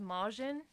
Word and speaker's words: Meijin: M-E-I-J-I-N. – It's pronounced Majin. – Is that Majin? Is that how you pronounce that? – Of the Boo family?